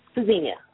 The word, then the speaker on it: Tzynya: Zina, is it? I pronounced that correctly? I'm not sure. Zina or Tzynya? – Tzynya. – Tzynya, okay.